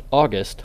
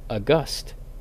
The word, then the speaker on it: August: The August Celestials is not pronounced August like the months. It's pronounced more like August, as in something or someone of great stature.